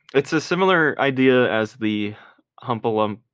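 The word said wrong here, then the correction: Heffalump: Huffalump Halloween special, where it's like, if we make it to Christmas...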